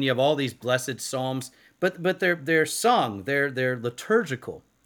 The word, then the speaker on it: Psalms: any sort of introductory material on the Psalms as a whole. – First, remember the P is silent.